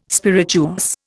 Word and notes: spirituous: Indian

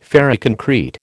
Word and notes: ferroconcrete: American English

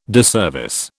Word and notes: disservice: British English

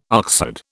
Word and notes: oxherd: British English

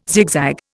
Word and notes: zigzag: Indian

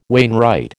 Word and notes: wainwright: American English

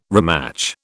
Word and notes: rematch: British English

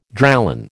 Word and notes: Dralon: American English

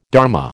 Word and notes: dharma: American English

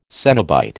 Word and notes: cenobite: American English

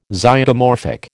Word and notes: Zygomorphic: American English

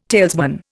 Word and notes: talesman: Indian

